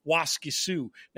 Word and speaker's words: Waskisu: That might be my worst pronunciation on the show since Alcibiades, JK, because yes, it's very clearly Waskisu now that I see it. I somehow got the S out of there completely.